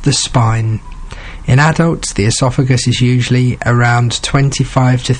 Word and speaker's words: oesophagus: How about something that doesn't sound like it begins with the letter O when you say it, but I'm going to say it. The esophagus. Yes. I bet you didn't expect me to talk about this today. It's spelled O-E-S-O-P-H-A-G-U-S, the esophagus,